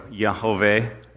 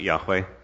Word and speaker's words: Yahweh: Yahweh or Yahweh or Jehovah. It's pronounced several different ways. I'll be pronouncing it Yahweh.